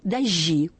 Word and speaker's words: дожди: То есть я стараюсь, чтобы они говорили «дожди», поскольку сценическая речь вообще тяготеет к традиции, если это не царапает слух.